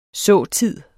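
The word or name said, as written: SÅTID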